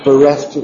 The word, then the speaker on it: bereft: Bereft empieza con B, pero la sílaba remarcada es reft. Entonces, eso va a marcar la literación y tenemos que esto literar con rule.